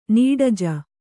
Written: ನೀಡಜ

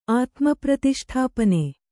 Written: ಆತ್ಮಪ್ರತಿಷ್ಠಾಪನೆ

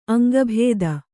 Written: ಅಂಗಭಿತ್ತಿ

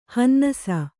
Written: ಹನ್ನಾಸಿ

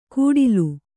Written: ಗುಡಿಲು

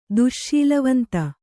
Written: ದುಶ್ಶೀಲವಂತ

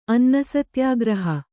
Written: ಅನ್ನಸತ್ಯಾಗ್ರಹ

